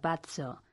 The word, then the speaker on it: pazzo: Pazo se pronuncia pazzo pero azurro, A-Z-Z-U-R-R-O, se pronuncia azurro.